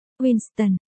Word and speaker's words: W1N5T0N: phát âm là Winston, không phải phát âm là W1N5T0N,